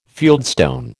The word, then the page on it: fieldstone: American English